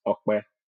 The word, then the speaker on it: Akbe: But the proper way to actually pronounce the short form of my name is Akbe.